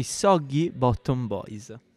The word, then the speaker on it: Soggy Bottom Boys: Portiamo i Soggy Bottom Boys.